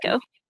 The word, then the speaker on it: Waco: I hope I'm pronouncing that right. – I think it's Waco.